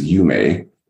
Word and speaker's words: yume: In Japan, the word for dream is yume. If I'm pronouncing that right, Y-U-M-E.